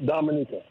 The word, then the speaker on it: Dominica: Right, but it's the natives, they pronounce it Dominica.